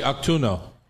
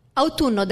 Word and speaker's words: autunno: How do you say autunno? – Autunno, that's correct.